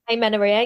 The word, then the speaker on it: amenorrhea: Amenorrhea, am I pronouncing that right? – Amenorrhea, yeah.